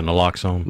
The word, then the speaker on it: naloxone: Uh, the hesitancy of some pharmacists to dispense naloxone. Is that did I say that right? Yeah, naloxone. Naloxone.